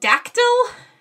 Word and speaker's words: Dactyl: So it's D-A-C-T-Y-L. Hill. – Like pterodactyl.